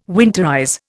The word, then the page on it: winterize: Indian